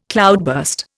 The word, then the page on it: cloudburst: Indian